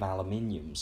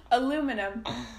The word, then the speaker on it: aluminium: Trying to pour special solutions on it and that, because they're made of aluminium, so they pour. Oh god. – Aluminum.